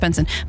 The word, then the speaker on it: BNXN: Yeah, but Benson is pronounced, but it's pronounced Benson, but it's spelt B-N-X-N, and that's what confused me.